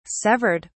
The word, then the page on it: severed: British English